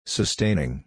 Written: sustaining

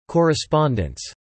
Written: correspondents

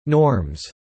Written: norms

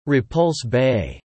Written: Repulse Bay

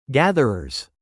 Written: gatherers

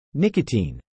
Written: nicotine

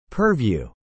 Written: purview

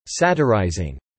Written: satirizing

